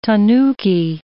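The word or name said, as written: tanya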